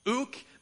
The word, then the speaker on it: ouk: Ouk, the first word with a K. Actually, with the English letters, it looks pretty similar to how it's pronounced, ouk.